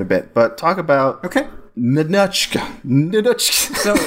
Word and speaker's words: Ninotchka: Let's start with Ninotchka, Dave. – So close. You're getting closer. You're getting closer to the right pronunciation. It's Russian, so yeah. Ninotchka. – Ninotchka.